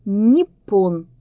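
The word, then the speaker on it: Ниппон (日本): Иероглифы «Жи», «Бэнь» на диалектах Южного Китая произносятся как «Япон». Такое звучание и перешло потом в европейские языки. А по-японски читается как «Ниппон».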